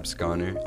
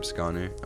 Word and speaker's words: Scanu: It's just my name, Gab Scanu, at Gab Scanu. – It's spelled S-C-A-N-U. – S-C-A-N-U, yeah.